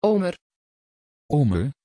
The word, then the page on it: ömer: Dutch